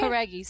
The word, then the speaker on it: Jauregui: Com vocês, Lauren Jauregui. Did I spell, did I pronounce it right? – Jauregui, sim.